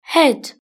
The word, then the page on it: head: British English